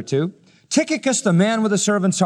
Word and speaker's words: Tychicus: The first one is a man named Tychicus. C-H is pronounced like a K.